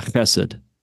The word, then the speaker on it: chesed: How do you say that, if you chesed. Really know what you're talking about? – Yeah, chesed. – Okay. That's what I said, right? Exactly. – Yeah, that's exactly what you said.